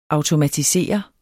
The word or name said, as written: automatisere